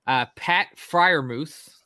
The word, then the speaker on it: Freiermuth: If we don't trade any picks, which is impossible, my 11 picks are Jamar Chase at 6, Pat Freiermuth. Did I say that correctly? – Yeah.